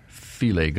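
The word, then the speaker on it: filé: as in filet gumbo. – Which is spelled F-I-L-E. I remember the first time I saw it in a recipe. Oh, yeah, the little accent.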